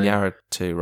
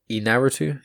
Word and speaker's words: Iñárritu: Iñárritu, right? Yeah. – Yeah. It's Alejandro Iñárritu.